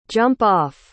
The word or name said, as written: jump-off